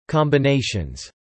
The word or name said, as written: combinations